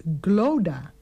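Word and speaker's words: Clodagh: Je spreekt het dus uit als Glodda.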